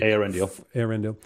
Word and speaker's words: Eärendil: and least of all while Eärendil, am I saying that right? Their lord was on the sea. – Eärendil.